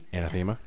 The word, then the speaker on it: Anathema: Anathema.